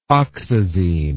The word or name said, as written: oxazine